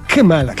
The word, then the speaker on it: Kamala: Se pronuncia Kamala. Se pronuncia ella.